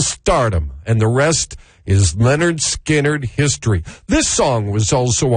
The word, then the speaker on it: Lynyrd Skynyrd: Lynyrd Skynyrd would release their debut album, pronounced Lynyrd Skynyrd, and a fine release for the debut album.